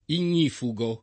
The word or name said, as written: ignifugo